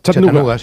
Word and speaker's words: Chattanooga: ¿Se pronuncia así en Chattanooga? – Chattanooga.